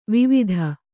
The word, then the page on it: ವೈವಾದ: kannada